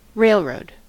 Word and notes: railroad: American English

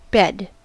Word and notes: bed: American English

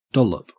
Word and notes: dollop: Australian English

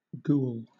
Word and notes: ghoul: British English

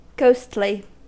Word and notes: ghostly: American English

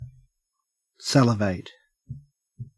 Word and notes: salivate: Australian English

